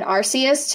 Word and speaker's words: Arceus: But this is what I had to do for Pokemon Arceus, too, because everyone was saying it's like a hard C, but I looked it up in Japanese and it's Arceus. Arceus or something.